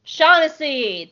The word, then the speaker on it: Shaughnessy: Shaughnessy. – David Shaughnessy. I know you corrected me in Season 1. That just, like, flashed back on me, like, really terrible deja vu. David Shaughnessy, who is also the voice of Oresco and Grunt in early seasons of Rebels.